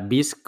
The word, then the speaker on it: Bisq: Pues nada, no, no, demoremos más y, saltemos al, tema que es eh, BISC, como decíamos antes, se escribe B-I-S-Q.